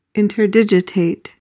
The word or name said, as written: interdigitate